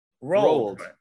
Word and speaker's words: Roald: Ronald Dahl's Witches. – Roald Dahl. There's no N in there. – Oh, I'm sorry. How's it pronounced? – Roald.